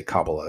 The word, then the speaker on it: Kabbalah: There were also um, early Jews that had mystical knowledge of reincarnation as well, and that will be in the the, uh, the Kabbalah or Kabbalah. I say Kabbalah; that's how I pronounce it.